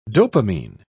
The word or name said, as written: dopamine